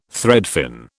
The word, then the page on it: threadfin: British English